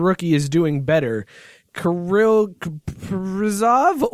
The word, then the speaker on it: Kaprizov: It's Kaprizov is how you do it, and that's how you Kaprizov.